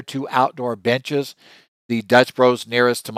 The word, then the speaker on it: Dutch Bros: So for instance, various parts of the country, we have Dutch Brothers, or Dutch Bros, I should say, if you're younger, I guess that's how you say it.